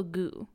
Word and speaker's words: Gout: It is called Piñata by Leopoldo Gu. It's spelled G-O-U-T, but it's pronounced Gu.